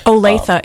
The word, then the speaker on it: Olathe: I'm not sure how to pronounce this last city. Olathe, is that? – Mm-hmm. Olathe, it's in Kansas.